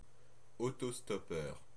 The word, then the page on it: autostoppeur: France (Normandie); [o.to.stɔ.pœʁ]